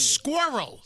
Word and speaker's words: squirrel: Listen, squirrel. – Squirrel. – Go red, red, red. – Don't feed the squirrels. – It's not one syllable. It's two.